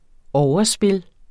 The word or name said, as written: overspil